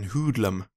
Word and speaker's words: hoodlum: Jag har alltid uttalat det hoodlum, men här säger de en hoodlum.